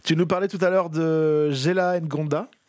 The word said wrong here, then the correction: Jalen Ngonda: ça se prononce comme ça ? – Jalen Ngonda, ouais, il vient de Washington DC et c'est un des coups de cœur aussi du festival.